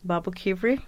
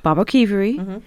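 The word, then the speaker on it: Baboquivari: You're going to have to correct my pronunciation, but Babo Kivari? – Babo Kivari. – Babo Kivari.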